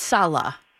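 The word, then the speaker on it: Saleh: I think it's Salah, like S-A-L-A, you know, pronunciation-wise.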